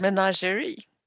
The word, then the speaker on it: Menagerie: Och vi får veta att Inej inte bara kan åka över hur som helst, hon tillhör ju eh, bordellen Menager, Menager kanske det uttalas? – Menagerie. – Menagerie till och med, tack så mycket. Min franska är lite sisådär, men menagerie, tack så mycket.